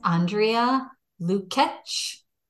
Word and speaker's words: Andrea Lukac: Her name is Andrea Lukac.